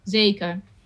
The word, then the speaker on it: zeker: So the R at the end, we don't pronounce as a R, not even in singing. Zeker, zeker. It's almost like you lose it a little bit, zeker.